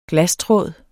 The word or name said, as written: glastråd